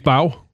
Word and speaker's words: bao: how do you say this? B-A-O. Is it on a bun? Is it a bow? – Bow.